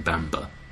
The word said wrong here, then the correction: Bamburgh: instead of Bamburgh, like Edinburgh.